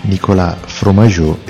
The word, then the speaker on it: Nicolas Fromageau: Anche in questo caso abbiamo Nicolas Fromageau e Anthony Gonzalez. L'ho pronunciato volutamente in francese perché, sapete, è sempre un po' diversa la pronuncia.